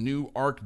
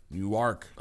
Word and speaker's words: Newark: New Ark. – Oh, wow. – Newark is in New Jersey. New Ark, spelled the same, is in Delaware.